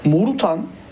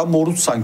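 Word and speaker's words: Moruțan: Birinci eleştirim Morutan veya Morutsan, kendisi Morutsan denmesini istiyormuş. Telaffuzu o şekildeymiş.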